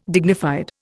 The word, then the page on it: dignified: Indian